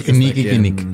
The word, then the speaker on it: Kinnickinnic: El 6 de septiembre de 1986 visitó el río Kinikinik. – Sí, es el Kinikinik. – K-I-N-N-I-C-K-I-N-N-I-C.